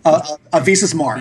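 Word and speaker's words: Visas Marr: If you make certain decisions and you side with, how do you pronounce her name? Is it Visus or Visus? Which way is it? – Visus Mar. – Visus, okay.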